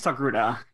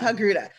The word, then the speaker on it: Togruta: Ahsoka Tano is a Togruta? Is that how you say it? Right? – I think it's Togruta. Yeah. – Togruta.